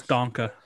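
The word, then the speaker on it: Danke: I don't care, but they're going to take this one three 0 All right, Dank. which of course is thank you in German, right? I'm pronouncing that one right, surely. Pretty sure it's Donka.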